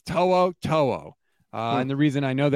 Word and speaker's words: To'o To'o: That's how you say it. To'o To'o.